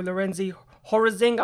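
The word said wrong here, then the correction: Lorenz Hörzing: I probably pronounced that. – Lorenz Herzing, yeah. – I knew I'd pronounced that incredibly wrong, you know, but I suppose you need to make a rule sometimes.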